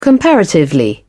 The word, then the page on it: comparatively: British English